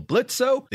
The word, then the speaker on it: Blitzo: Fellow Blitzo, the O is silent,